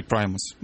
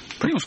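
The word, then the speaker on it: Primus: Это будет Лес Клейпул, такой есть американский музыкант, а его группа Примус или Праймус. Наверное, Праймус, правильно говорить, да? – Примус, как-то по-нашему. – Примус по-нашему. В общем, их Праймус, наш Примус.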